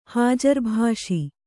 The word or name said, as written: ಹಾಜರ್‍ಭಾಷಿ